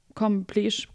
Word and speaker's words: completion: Because this card is at the end of the Major Arcana, was auch immer das bedeutet, it represents the com completion, completion, com, com Kompletion of something. ah, klasse. Wie spricht man das aus? Was, wie wird es denn geschrieben? – Completion. Kompletion. Completion. Completion. Okay.